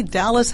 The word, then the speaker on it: Dahlys: Well, number one, I'm on Facebook, and my first name is spelled D-A-H-L-Y-S. So I'm the only Dallas Hamilton on Facebook that I know of.